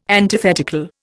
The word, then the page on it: antithetical: Indian